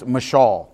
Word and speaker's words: mashal: It's the Hebrew word mashal. It's spelled M-A-S-A-L, but it's pronounced mashal, okay?